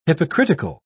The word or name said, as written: hypocritical